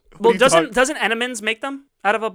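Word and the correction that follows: Entenmann's: I'm from Long Island. You don't pronounce shit. – Enemans. They. – Entenmans.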